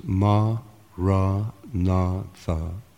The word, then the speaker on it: Maranatha: And if you choose this word, say it has four syllables, ma, ra, na, tha.